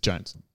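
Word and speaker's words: Jonze: That's how you pronounce it. – Mm. – It's Johns or Jones, but it's Jones. just with a Z in it.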